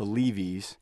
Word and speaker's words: Leevees: Yeah, we're called the Leavies. It's an unusual spelling. It's spelled L-E-E-V-E-E-S. Think of the Bee Gees if they were Jews.